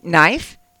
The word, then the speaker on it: knife: Knife. The K is silent.